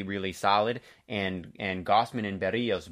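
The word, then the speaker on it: Berríos: But they have a whole season of Jose Berrios. Berrios, I should say, is the correct pronunciation.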